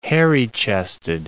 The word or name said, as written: hairy-chested